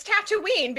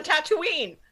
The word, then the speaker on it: Tatooine: It's Han. Yeah. He introduces himself as Han, and it's Tatooine because the people who live there say, I live on Tatooine.